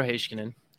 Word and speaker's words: Heiskanen: You pronounce Haskinen with a sh. Is that the correct pronunciation? All year I've wanted to ask this question. So I'm telling you, Calgary Puck, he started doing it. So I view as a little bit smarter than me, so I started doing it. So if wrong, it's him. – It's Miro Heiskanen, okay. And it is that is how the Finns have told me is pronounced.